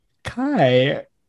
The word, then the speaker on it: Chi: Round two is Kai. The C spelled like C-H-I in English, where it's the X in Greek.